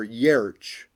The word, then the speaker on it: Giertz: or Yerch, as uh, it's actually supposed to be pronounced, but us Americans we just butcher names for the fun of it.